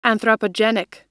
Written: anthropogenic

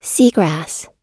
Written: seagrass

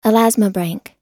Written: elasmobranch

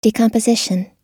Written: decomposition

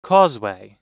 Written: causeway

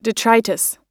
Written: detritus

